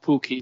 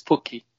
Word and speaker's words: Pukki: It's not Pukki, it's Pukki. Thank you.